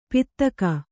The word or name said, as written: ಪೀತಕ